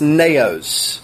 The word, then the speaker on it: naos: If you look at the outline there, there's a word, and it's supposed to, there should be a tick mark over the O there, and it's pronounced naos.